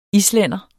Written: islænder

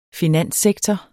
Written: finanssektor

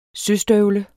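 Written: SØSTØVLE

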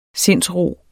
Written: sindsro

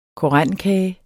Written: korendekage

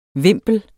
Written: vimpel

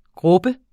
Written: grubber

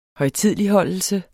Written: højtideligholdelse

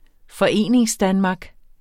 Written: Foreningsdanmark

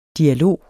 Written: dialog

